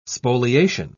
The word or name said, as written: spoliation